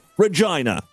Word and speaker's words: Regina: The answer is Regina. And yes, it's pronounced Regina.